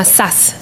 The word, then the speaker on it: Zaz: se llama Sass, se escribe Z-A-Z,